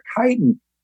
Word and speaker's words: chitin: What is chitin? – Chitin. – Okay. – Chitin, yeah, and it's pronounced like chitin. It's C-H-I-T-I-N, but it's called chitin.